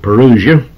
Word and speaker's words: parousia: And so that there'll be no mistaking about it, that word is spelled P-A-R-O-U-S-I-A. It's a word that comes right to us in the Greek. Some people pronounce it parousia. I pronounce it parousia.